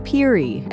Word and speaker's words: Peary: Robert Peary, as it's more commonly pronounced, was an American whose complicated story is part of a longer, larger story of a changing Greenland.